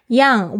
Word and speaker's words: young: Young, Y-O-U-N-G, 这边呢要注意一下那个 NG 的发音哦，它是这个后舌，就是你舌头的根部翘起。